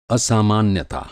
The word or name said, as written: असामान्यता